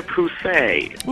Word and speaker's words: Pussey: And on this chat line, we were discussing weird names, and someone mentioned that there was a French guy whose name was spelled P-U-S-S-E-Y. Yes. P-U-S-S-E-Y. – Okay. – And he pronounced it Mr. Pousset.